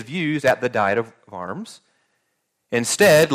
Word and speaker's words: Worms: It's pronounced, it's spelled like worms, but it's pronounced Worms, in Worms, Germany.